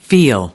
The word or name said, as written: feel